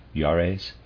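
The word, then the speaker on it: Jaurès: Remember what I told you about Juarez, pronouncing this word, readers aside, J-A-U-R-E-S, Juarez.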